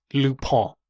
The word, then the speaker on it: Lupin: It is Lupin. – Oh, okay. I have heard of that. How did you pronounce that again, though? – Lupin. – Yeah, okay. You do better than most people that I've heard pronounce it, which say Lupin.